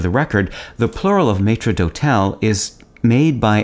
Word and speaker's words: maître d'hôtel: The term maitre d' is short for maître d'hôtel. In French, it's pronounced maître d'hôtel.